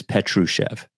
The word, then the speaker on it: Petrushev: By the way, we got a note on YouTube about pronunciation. You are half right. I am all the way wrong with Petrushev. So it's Petrushev. So you always do the SH, which I never do. But it is Petrushev, not Petrushev.